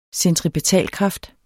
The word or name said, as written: centripetalkraft